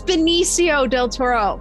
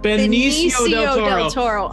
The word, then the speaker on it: Benicio del Toro: It's Benicio del Toro. – Benicio del Toro. – I'm so sorry. – Thank you, miscellaneous editor. – Yeah. – Thank you. Benicio del Toro.